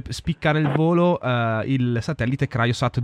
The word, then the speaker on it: CryoSat: Come si pronuncia correttamente, dottor Parrignello? – Ma ci sono diversi modi, praticamente vanno bene entrambi, però noi siamo più abituati a Cryosat.